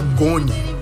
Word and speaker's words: ngoni: E, por fim, Bassekou Koyaté, que toca um instrumento que chama Goni. O N é mudo, N-G-O-N-I, com a participação de Zumana Tereta.